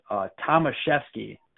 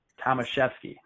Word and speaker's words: Tomaszewski: But it is Tomaszewski. So it's like Tom, who is chef, and he skis, Tomaszewski.